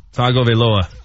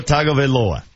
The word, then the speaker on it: Tagovailoa: Tagovailoa. – Very good. Tua Tagovailoa.